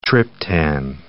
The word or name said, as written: triptan